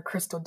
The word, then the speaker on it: Krystle: Well, I am on Facebook under Crystal Delahue, of course. Instagram K-R-Y. – Yeah. – right. Got to spell it. K-R-Y-S-T-L-E.